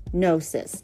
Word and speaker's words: gnosis: Now, Gnosticism, by definition, came from the Greek word for knowledge, gnosis, N-G-O-S-I-S. G is silent.